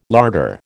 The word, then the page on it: larder: American English